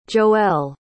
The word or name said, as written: joelle